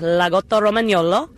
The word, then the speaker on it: Lagotto Romagnolo: It's pronounced Lagotto Romagnolo. Otherwise, we just call them Lagotto.